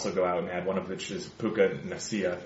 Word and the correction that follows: Puka Nacua: But he's Puka Nacua, I believe. – Puka Nacua,